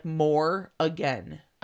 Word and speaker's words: Morrigan: It's spelled Morrigan, but it's pronounced more again. – Okay. I wasn't quite sure if it was Morgan.